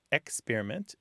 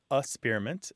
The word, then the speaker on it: experiment: So one of the ways the pronunciations of experiment differ is that first syllable. So some people, it's experiment, and some people it's a-spearment, and some people it's ick-spearment.